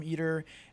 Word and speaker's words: ITER: Did I pronounce that correctly? – Yep, I've been saying ITER forever. I apologize to all the ITER people.